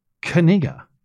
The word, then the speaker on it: Knigga: Now, in comes earlier this year, Indiana high school linebacker prospect Noah Kaniga. It's pronounced this way. K-N-I-G-G-A. – Kaniga. – That's how you say Kaniga.